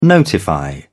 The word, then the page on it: notify: British English